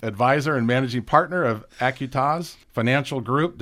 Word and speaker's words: Acuitas: Did I pronounce it right this time? – Close. Acuitas. – Acuitas. – It's Latin, so don't beat yourself up. – Acuitas. I know what I did wrong. See, I split it up and I didn't put a Q in it.